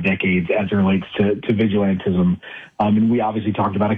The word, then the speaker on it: vigilantism: By the way, our producer looked it up, and it is, in fact, vigilantism. I've been mispronouncing it as vigilanteism. That is not correct.